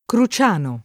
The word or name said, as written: Cruciano